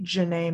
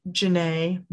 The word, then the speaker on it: Jenay: And then personal Instagram is Jazzy Jenay May at Jazzy Jenay May, M-A-Y. That's my middle name. – And Jenay is spelled J-E-N-A-Y, right? – Yes.